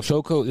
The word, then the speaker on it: Shoko: Is it Shoko? You pronounce it for me, because I always fuck up names. – Shoko.